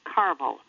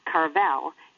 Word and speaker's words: Tarbell: The sound of my first name also complements my last name, Carvel, which is my husband's surname, Carvel, with the accent on the first syllable, not Carvel, as most people think.